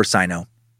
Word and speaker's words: Sino: And that war is pronounced Sino or Sino, just so you know.